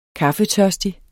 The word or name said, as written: kaffetørstig